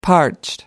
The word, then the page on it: parched: American English